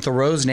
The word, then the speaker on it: Theroux: We've been saying Justin Theroux's name incorrectly this whole time. His uncle is an author, and he says they've always pronounced it Theroux because it's French.